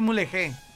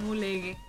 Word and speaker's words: Mulegé: Recomiendo que enseñen al gago a decir mulegé. Ahí está, lo dije bien, ¿no? – Ahí está. Mulegue.